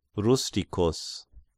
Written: Rusticus

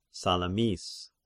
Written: Salamis

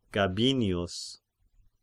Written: Gabinius

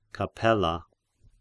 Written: Capella

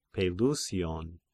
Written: Pelusion

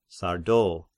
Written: Sardo

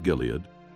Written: Gilead

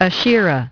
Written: Asherah

